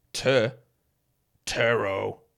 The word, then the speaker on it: Turo: These notes on this ad said Turo is pronounced T-E-R-R. Tur. Turo.